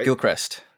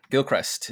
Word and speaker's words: Gilchrist: Gilchrist. Gilchrist is how in the book it is. – So it's spelled G-I-L...